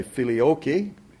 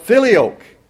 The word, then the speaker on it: filioque: It's a Latin term called filioque, or as some would say, filioque, depending upon how you pronounce the Latin word. But filioque.